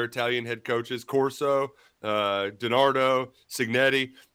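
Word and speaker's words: Cignetti: Signetti, is that right? Signetti? – Kurt Signetti.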